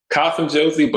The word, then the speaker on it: Kasim Josey: Kasim Josey, but we're like close enough, man.